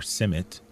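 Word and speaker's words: CIMMYT: She's the Maize Land Race Improvement Coordinator at the International Maize and Wheat Improvement Center, or CIMMET, as it's more commonly pronounced.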